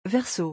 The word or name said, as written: verseau